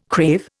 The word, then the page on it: crave: Indian